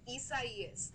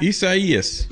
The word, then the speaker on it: Isaias: Isaias. That's how you pronounce the name of the system that we have. – Isaias. – Isaias.